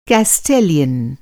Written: केस्टेलियन

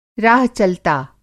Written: राह-चलता

